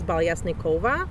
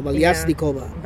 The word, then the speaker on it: Valiasnikova: Yeah, I think like an anglicized version is Valiasnikova. In Russian is Balesnikova. – Oh, Valiasnikova. – Valiasnikova. – So it's more like a little, the accent is in another syllable, Valiasnikova.